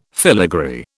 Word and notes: filigree: British English

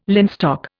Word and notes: linstock: Indian

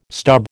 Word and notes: stub: American English

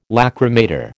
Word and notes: lachrymator: American English

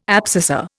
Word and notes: abscissa: Indian

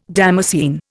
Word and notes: damascene: Indian

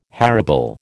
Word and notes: harebell: American English

